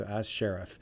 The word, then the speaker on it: Sharif: We heard As Sharif doing When She Comes. I'm sure I'm pronouncing the name wrong. It's A.S. and then basically Sheriff. So As Sheriff from British Columbia, Canadian Hippies from the Six Ways to the Ace collection.